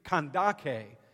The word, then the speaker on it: Kandake: It looks like Candace when you read it in English, but it's pronounced in the Greek Kandake.